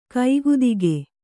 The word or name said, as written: ಕೈಗದಕ